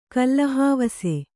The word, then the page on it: ಕಲ್ಲಹಾವಸೆ: Kannada; kallahāvase